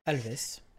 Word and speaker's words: Alves: Je sais pas ce que ça se prononce Alves ou Alve, je ne sais pas d'ailleurs. – Alves. – Galvez, ok, parfait.